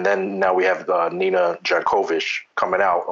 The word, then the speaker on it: Jankowicz: And you said it right. It's Jankowicz. It's not Jankowicz. It's Jankowicz, just how she said it.